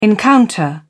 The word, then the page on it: encounter: British English